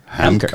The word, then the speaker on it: Haemker: Haemker.